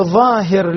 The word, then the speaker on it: ظاهر: لاحظ البيت اللي بعده: ظاهر لظى شواظ كظم ظلما، أغل الظلام ظفر انتظر ظما. نفس الشيء، كلمات ذكرها ابن الجزري كلها تنطق بالظاء وليس بالضاد، بالظاء وليس بالضاد.